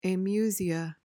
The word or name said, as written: amusia